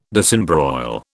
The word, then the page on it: disembroil: British English